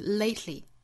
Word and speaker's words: lately: 首先我们来看第一个发音要点，第一个单词 lately。 Lately, 这个单词里包含了爆破音 t 和舌侧音的组合，一般来说这两个音如果相遇需要连在一起读的时候，是有一个舌边爆破的发音方法。我们注意在英音之中还是发成 lately lately，在发 t 的同时去发舌侧音那当然在这个单词之中还有一点点特殊，就是后面还有一个元音，所以在发的时候呢注意是 tli tli tli，最终有一个 e 的发音。